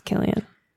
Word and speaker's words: Cillian: And his name's pronounced Killian.